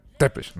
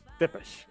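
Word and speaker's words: Țepeș: Le voy a copiar mis salud este infeliz y me encanta, dice, este, exanguinador profesional, Vi Tepes, dice, recuerde, se pronuncia Tepes, ¿no? Tepes, exacto.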